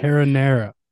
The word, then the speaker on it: Cara Neir: Cara Neir.